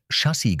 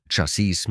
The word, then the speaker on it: Chassis: Der Begriff Chassis im Bereich Technik bzw. Auto sollte korrekt Chassis gesprochen werden. KI macht oft Chassis mit T daraus oder setzt die Betonung auf die zweite Silbe wie im Französischen.